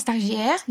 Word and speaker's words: Stagiaires: Stagiaires, yes.